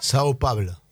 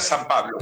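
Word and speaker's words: São Paulo: O sea, ahí me dice, no quiero escuchar que diga San Pablo. – Es Sao Paulo.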